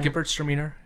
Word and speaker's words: Gewürztraminer: Gewürztraminer. There's a W in there just to pronounce it as a V.